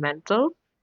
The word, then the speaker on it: MNTL: I just wanted to go into what we were talking about, the name Mental. And so we're called Mental, but, you know, it's spelled M-N-T-L. So we removed the vowels.